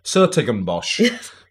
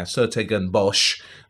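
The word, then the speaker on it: 's-Hertogenbosch: And in this fabulous little town called Sertogenbosch. Which I'm delighted to be able to pronounce properly there. That took me a whole of years practice to do that.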